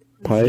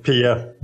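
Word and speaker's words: Piye: Pie? Yeah, I just learned that I probably have pronounced it wrong in the YouTube show. It's Pia.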